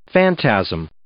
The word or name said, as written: phantasm